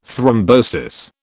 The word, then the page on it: thrombosis: British English